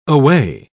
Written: aweigh

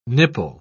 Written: nipple

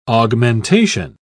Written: augmentation

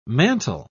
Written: mantle

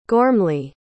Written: gormley

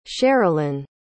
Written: sherilyn

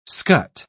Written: scut

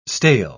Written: stale

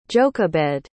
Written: jochebed

